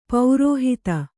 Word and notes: ಪೌರೋಹಿತ: paurōhita

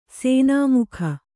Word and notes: ಸನಾಮಕ: sanāmaka

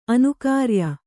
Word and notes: ಅನುಕಾರ್ಯ: anukārya

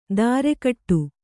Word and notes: ಧಾರೆಗಟ್ಟು: Kannada

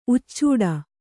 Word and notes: ಉಚ್ಚೂಡ: uccūḍa

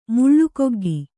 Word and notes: ಮುಳ್ಳುಗೊಗ್ಗಿ: muḷḷugoggi

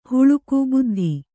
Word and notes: ಬುದ್ದಿ: buddi

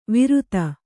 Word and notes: ವಿರುತ: Kannada

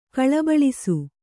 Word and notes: ಗಲಬಲಿಸು: kannada

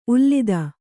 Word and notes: ಉಲ್ಲಿದ: ullida

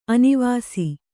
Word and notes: ಅನಿವಾಸಿ: kannada

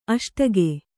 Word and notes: ಅಷ್ಟಗೆ: aṣtage

